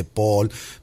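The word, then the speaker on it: Paal: Paul, se dice Paul, pero se escribe con A porque viene de Suecia.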